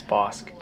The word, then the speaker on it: Bosque: B-O-S-Q-U-E. – Oh, Bosque. – Yeah, see, it's not right. It's Bosque.